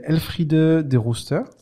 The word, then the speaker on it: Elfriede de Rooster: Cette artiste, elle s'appelle Elfride de Rooster. – C'est bien prononcé.